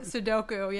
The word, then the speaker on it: Sudoku: And is it, I always say it wrong. Sudoku? Is that what? – Sudoku, yeah.